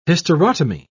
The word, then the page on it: hysterotomy: British English